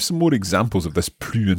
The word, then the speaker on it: plus: And note that S there on plus. We have to pronounce it because it's more time. It's not no more time, but more time.